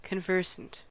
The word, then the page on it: conversant: American English